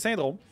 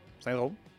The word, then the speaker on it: SXNDRXM: Il y a quelqu'un qui me demandait cette semaine comment tu prononces le nom du band. C'est Syndrome. S-X-N-D-R-X-M. Syndrome. C'est de même, ça s'écrit.